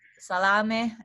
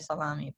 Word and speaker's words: salame: People call it salami, but to me it's salame. You know, that's how you pronounce it in Italian. – What does it have at the end? – Because it got an E at the end. – Exactly. And how are we supposed to pronounce an E at the end of an Italian word? – E. So exactly. If you have an E at the end of salami, technically it's salame.